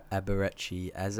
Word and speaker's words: Eberechi Eze: Yeah, they've got talent up front, and we can go into Eberechi Eze.